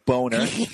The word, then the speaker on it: Boehner: It's B-O-E. That's Bo. H-N-E-R. That's Ner. Boner. – And you spelled it B-O-N-E-R as well.